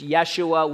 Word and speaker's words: Yeshua: By the way, Jesus, actually, we've been mispronouncing it for years. It's Yeshua, which is actually Joshua.